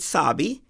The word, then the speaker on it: Sabi: It's Sabi, S-A-B-I. It always draws a good laugh when I say it's just like Kemosabi.